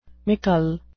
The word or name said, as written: mickle